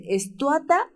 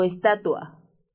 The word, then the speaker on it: estatua: Bueno, pues hay gente que dice estuata en lugar de estatua.